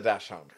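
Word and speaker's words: dachshund: I always pronounce it wrong, but I do know the name of this. Is it a dash hound? – It is a dash hound, correct.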